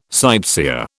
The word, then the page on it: sightseer: British English